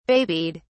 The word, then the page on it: babied: British English